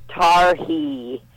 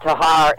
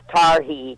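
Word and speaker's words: Tarhe: That's pronounced Tar-hee. The Boy Scouts had a... it was part of their ritual. And it's not Tahar, it's Tar-hee, Chief Tar-hee. And there was quite a big thing about him. – So T-A-H-R-E is Tar-hee? – Tar-hee.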